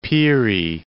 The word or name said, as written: Peary Land